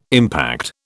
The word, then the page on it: impact: British English